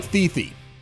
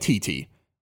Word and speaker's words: Thithi: Now, it looks like Thithy. And the only reason I'm telling you this is because when you go to the website later, you have to spell it T-H-I-T-H-I.